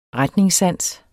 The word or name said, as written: RETNINGSSANS